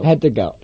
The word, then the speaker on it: had to go: Had to go. – We kind of don't say the T, we just say had to go.